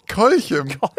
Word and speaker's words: Kolchem: Lange hat sie auf den neuen Roman von Jannis Wie spricht man den aus? – Kolchem. – Kolchem.